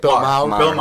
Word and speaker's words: Maher: Bill Maher. – I said it right the first time? – Maher.